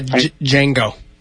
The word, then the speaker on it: Django: So we watched Django. – Oh, I haven't seen it. – The D is silent. – Yeah, Django.